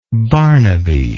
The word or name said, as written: Barnaby